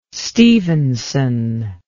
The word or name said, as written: stephenson